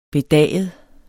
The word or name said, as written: bedaget